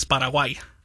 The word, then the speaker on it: Paraguay: It's the Spanish pronunciation is Paraguay.